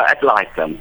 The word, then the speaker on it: ad litem: Is that how you pronounce it? – All right. Yeah, ad litem.